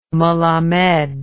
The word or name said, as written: melammed